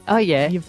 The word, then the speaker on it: Yves: It's Yves Saint Laurent, and I think it's Yves. – That's good. – What's the second bit? – Yves. I don't know. – Throw your Ocker Aussie back into it. Yves.